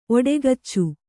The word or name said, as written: ಔಡುಗಚ್ಚು